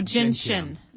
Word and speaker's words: gentian: And gentian, is that sometimes pronounced ginseng? – No, gentian. Yeah.